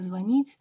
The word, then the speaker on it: звонить: что звонить именно звонить, что не обеспечение, а обеспечение.